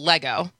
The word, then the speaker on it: Lego: So your big thing is still Lego.